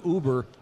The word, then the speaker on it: Uber: We found out that the term Uber rhymes with lube her.